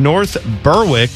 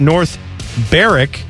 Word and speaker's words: North Berwick: My tool of the week is you, Mike, and Timmy for pronouncing North Berwick as North Berwick. It's actually pronounced North Berwick. The W is silent.